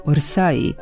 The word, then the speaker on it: Orsay: Kaže se Orsay, bez J na kraju, a potvrda se može naći u pravopisu srpskog jezika.